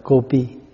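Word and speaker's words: Gopī: À, tiếng Phạn đọc là Gopi, mình đọc âm lại là Cù Di.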